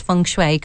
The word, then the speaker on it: feng shui: I hope I pronounced feng shui correctly. – You have, actually. Well done.